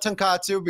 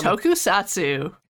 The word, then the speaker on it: Tokusatsu: I always mispronounce this. It's not Tonkatsu because that's ramen. – Tokusatsu. – It's one of... They're very similar looking words, y'all.